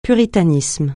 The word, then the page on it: puritanisme: francais